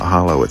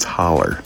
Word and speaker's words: holler: And up in the holler, I know it's spelled hollow, but it's not hollow, it's holler.